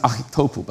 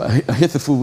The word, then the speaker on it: Ahithophel: In the Hebrew, it's pronounced Ahitophel, but I didn't want to spit on anybody in the front row. So Ahithophel would do.